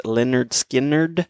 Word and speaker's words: Lynyrd Skynyrd: Came out in 1973 off of their debut album, pronounced Lynyrd Skynyrd.